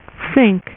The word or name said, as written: Synch